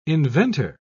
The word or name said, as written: inventor